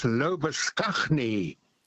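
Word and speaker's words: Labuschagne: Now, Mr. Lobashane's name in South Africa, where I spent my youth, was and still is pronounced as Lobaskagni.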